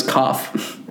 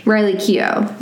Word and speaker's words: Keough: I want to pronounce her last name as Koff. – Koff? Maybe it is. – K E O U G H. Oh, it's actually Riley Keough. That's how you say it, because it says it here.